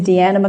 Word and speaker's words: Deanna: So my name is Deanna McLeod. That's D-E-A-N-N-A. McLeod is M-C capital L-E-O-D.